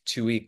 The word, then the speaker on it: Towhey: Yes, you can find me on Instagram at Jack Tuohy Calk.